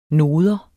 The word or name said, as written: noder